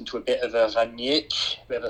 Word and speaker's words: Rangnick: Rangnick. Ralf Rangnick. – We're Rangnick, yeah. – You need your emphasis on the first part of the word, so Rangnick. – Rangnick. – And practice rolling your R's.